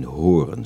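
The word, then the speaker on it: Hoorn: Maar tegenover mij zit een voorganger van de Pinkstergemeenschap in Horen. Zeg ik dat goed? Ja, dat zeg je goed.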